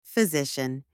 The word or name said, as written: physician